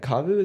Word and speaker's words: Kavu: Kavu is how you say Kavu.